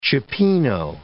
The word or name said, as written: cioppino